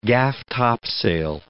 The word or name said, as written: gaff-topsail